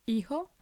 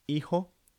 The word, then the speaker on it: hijo: which is the J sound is just like an English H sound, so it's not sort of doesn't make a large noise, loud noise, it's just hijo. So the spelling for son is H-I-J-O, and they say hijo.